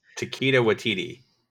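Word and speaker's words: Taika Waititi: I'm telling you, the final episode, which is directed by the director, I'm going to butcher his name, Waikiki Tahiki, whatever his name is. – Takeda Waititi.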